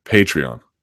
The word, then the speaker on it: Patreon: So, Patreon is how you pronounce that word, apparently. Patreon.